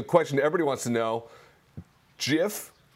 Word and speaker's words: GIF: I would call it a GIF. – All right. Sold. – I'm going to say that's a hard G there.